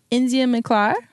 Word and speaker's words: India McClar: India McClar. – Spell it. I-N-D-I-A. – Mm-hmm. M-C-C-L-A-R.